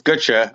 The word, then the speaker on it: Gutsche: Yeah, it's Gutsche. I get Gucci, Gooch.